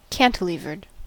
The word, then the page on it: cantilevered: American English